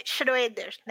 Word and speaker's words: Schroeder: Aí meu último sobrenome é S-C-H-R-O-E-D-E-R. Se escreve Schroeder, né? A gente fala Schroeder.